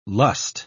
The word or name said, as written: lust